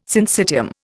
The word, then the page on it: syncytium: Indian